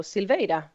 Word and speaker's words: Silveira: No, Silveira. That was perfect. Okay. Yeah. – All right. So, and then Silv, like Victor, Eyra.